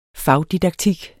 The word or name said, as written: fagdidaktik